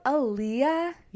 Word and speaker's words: Aaliyah: You're talking about Aaliyah? – Yeah. Am I saying her name right? – Yes. – Okay. – A-A-L-I-Y-A-H.